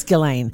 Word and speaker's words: Ghislaine: It's all because him and Ghislaine, is that how you pronounce her name? – I think it's Ghislaine.